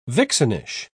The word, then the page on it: vixenish: British English